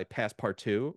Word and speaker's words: Passepartout: And this guy, Passepartout, which this looks like almost like it's spelled like passport, which is great.